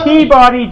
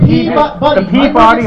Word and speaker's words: Peabody: They say Peabody down at the Peabody Hotel in Memphis. It's Peabody here.